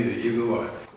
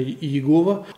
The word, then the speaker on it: Иегова: А вы знаете, на самом деле они на полном серьезе выступают в пику этому мнению и утверждают, что нет, это неправильно, приводят свои аргументы в пользу того, что правильно произносить именно Иегова.